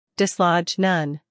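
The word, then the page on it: dislodge: uk dɪsˈlɒdʒ; en-us dɪsˈlɑːdʒ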